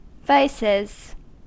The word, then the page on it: vices: American English